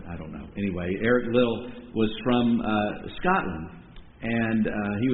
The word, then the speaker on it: Liddell: I've wondered if there might be a similar sort of pressure that Eric Little or Liddell, Little I believe is how it's usually pronounced, I wonder if it's the same pressure he felt when he declined to run on a Sunday in the 1924 Olympics.